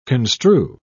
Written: construe

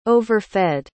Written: overfed

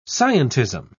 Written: scientism

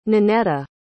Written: ninetta

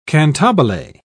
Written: cantabile

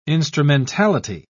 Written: instrumentality

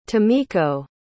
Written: tomiko

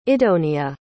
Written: idonea

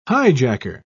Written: hijacker

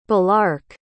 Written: balark

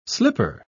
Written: slipper

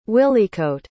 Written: wilycoat

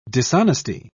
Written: dishonesty

